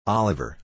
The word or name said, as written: Oliver